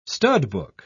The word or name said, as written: studbook